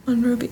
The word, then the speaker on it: Monrovia: Like, they were just kind of stuck in Monrovia. Yeah, is that how you say it? That was where they landed. – Monrovia. That's how I would say it.